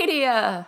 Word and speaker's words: Nadia: And the way that I like to teach people to say my name is to tell them to think about how a horse would say it. So I think that a horse, if a horse was saying my name, which would be pretty spectacular, I think the horse would say, Nadia!